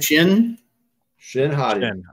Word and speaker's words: Shin Hati: because it's like those scenes with Baylan and Shihati. Did I say her name right? – Shin Hati. – I said Shihati. Shihati. Shin-Hati.